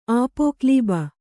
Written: ಆಪೋಕ್ಲೀಬ

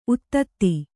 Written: ಉತ್ತುತೆ

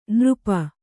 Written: ನೃಪ

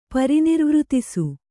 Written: ಪರಿನಿರ್ವೃತಿಸು

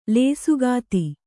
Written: ಲೇಸುಗಾತಿ

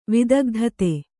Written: ವಿದಗ್ಧತೆ

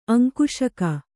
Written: ಅಂಕುಶಕ

